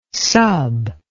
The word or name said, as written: sub